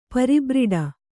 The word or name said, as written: ಪರಿಬ್ರಿಡ